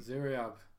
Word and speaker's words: Ziryab: So, today we're going to talk about Ziryab. – Mm. – Ziryab. – Can you spell that for us? – You know him. Z-I-R-Y-A-B.